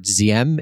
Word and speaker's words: Diem: I've already heard Diem. Is that not correct? That's broadly. – Oh, that's correct. I speak a Southern Vietnamese accent, so I'm going to say Diem if that's all right.